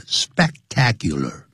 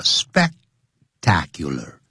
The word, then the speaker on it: spectacular: 干什么呢 ?To enjoy the spectacular shows and ambience, 来享受这种壮观的 spectacular, 因为有一个 SPEC, 所以念的时候要停顿一下 spectacular, spectacular, 不要念成 spectacular, spectacular,